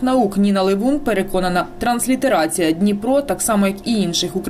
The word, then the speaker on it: Дніпро: Частина науковців переконана, ця назва має однаково вимовлятись і українською, і російською, і англійською – Дніпро.